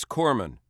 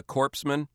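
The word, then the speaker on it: corpsman: It is Corman. You don't pronounce the S. Yeah, I mean, it's not very good for a guy delivering first aid to be called a corpseman.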